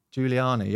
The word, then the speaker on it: Giuliani: Giuliani, yep.